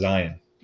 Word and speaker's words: Zion: Yeah, his name is actually pronounced Zion. – Zion? – Like lion with a Z.